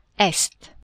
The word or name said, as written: est